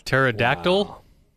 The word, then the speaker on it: pterodactyl: Pterodactyl? – I guarantee you it has been done. – People forget that the P is silent in pterodactyl.